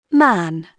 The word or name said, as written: man